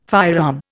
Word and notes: firearm: Indian

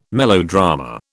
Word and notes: melodrama: British English

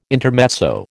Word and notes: intermezzo: American English

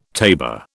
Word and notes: tabor: British English

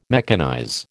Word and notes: mechanize: American English

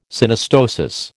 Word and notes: Synostosis: American English

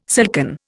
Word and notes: silken: Indian

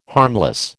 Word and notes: harmless: American English

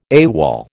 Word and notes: AWOL: American English

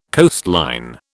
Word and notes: coastline: British English

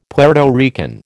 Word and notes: Puerto Rican: American English

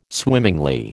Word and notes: swimmingly: American English

